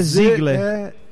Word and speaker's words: Ziegler: Não, é porque a pronúncia é Ziegler. – Z-I-E-G-L-E-R, mas a pronúncia é Ziegler. – Ziegler. – Que é de origem alemã.